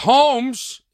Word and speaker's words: Holmes: Holmes. Am I saying that right? – Yeah.